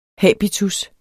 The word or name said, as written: habitus